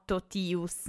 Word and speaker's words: totius: Ad esempio nella parola totius, allora leggiamo totius, non leggiamo la Z